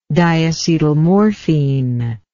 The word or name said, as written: diacetylmorphine